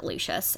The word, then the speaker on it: Lucius: Jim Dale pronounces it Lucius, and I trust him in all things.